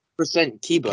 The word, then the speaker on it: Kiba: It's K-I-B-A, so... – Yeah. – % Kiba. – Kibbles. – The show? – What? – I said it's Kiba.